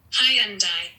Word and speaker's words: Hyundai: How to pronounce Hyundai. – Yeah, that is a hard one. – And? – Hyundai.